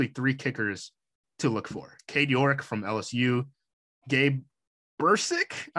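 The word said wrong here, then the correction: Brkic: I think is how you say his name, Berkich. – From Oklahoma. – Berkich.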